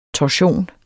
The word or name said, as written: torsion